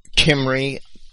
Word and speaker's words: Cymry: Kimry, C-Y-M-R-Y, it's spelled in English, and Kimbri.